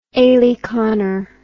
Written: aleconner

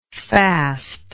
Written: faster

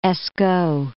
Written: Escaut

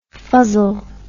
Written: fuzzled

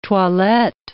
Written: toilette

